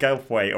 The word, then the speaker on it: Goldthwait: And they answer the phone to what I believe to be Bobcat Goat. I can't say his name. Bobcat Goldthwait, or whatever his name is, from Police Academy 2.